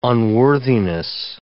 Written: unworthiness

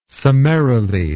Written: summarily

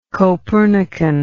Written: copernican

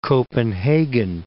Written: Copenhagen

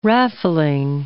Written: raffling

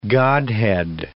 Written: godhead

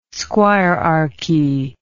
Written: squirearchy

